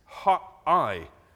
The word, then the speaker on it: Ha'ai: Ai, it shouldn't be pronounced Ai. It's in Hebrew, it's the heap. It's Ha'ai. It's like the other way around. It's not Ai.